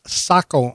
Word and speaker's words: Saco: but when I pronounced it as Seiko again, I just figured I was too ignorant to learn, but it is Saco, Maine, and they've been up there for quite a few years.